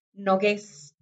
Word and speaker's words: Nogués: Nico Nogués creó así, Nico, con seca, Nogués, con acento en la E, por favor.